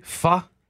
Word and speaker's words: pho: did I just pronounce that correctly? Pho? – Yeah, it's spelled P-H-O, mm-hmm.